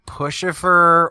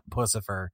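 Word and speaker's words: Puscifer: I've heard like Pushifer or like Pussifier, and like different, like they're trying to like intentionally enunciate it wrong so that it's not Pussifer.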